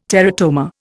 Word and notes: teratoma: Indian

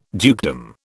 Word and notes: dukedom: British English

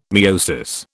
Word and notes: miosis: British English

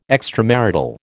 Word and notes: extramarital: American English

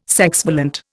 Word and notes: sexvalent: Indian

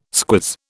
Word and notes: squiz: British English

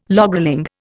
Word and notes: logrolling: Indian

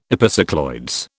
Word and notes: epicycloids: British English

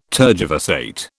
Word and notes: tergiversate: British English

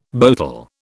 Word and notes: boatel: British English

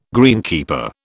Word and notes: greenkeeper: British English

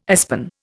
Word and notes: aspen: Indian

